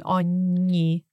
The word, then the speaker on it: ogni: And just for our listeners, be careful when you pronounce it. It's a GN sound, pronounced in a sweet way. So ogni. So it's not gn, but yep. It's gn.